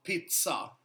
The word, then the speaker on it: pizza: ZZ blir TS på italienska. – Ja. – I uttalet. – Ja. – Så mozzarella. – Ja. – Pizza.